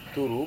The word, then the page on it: turu: Serewen